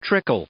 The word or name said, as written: trickle